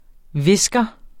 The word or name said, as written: whisker